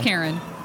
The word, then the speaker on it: Keren: It is honestly K-E-R-E-N, which nobody else has ever seen. Most people pronounce Kieran if they don't know. – It's Karen. Same old boring Karen.